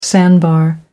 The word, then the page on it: sandbar: American English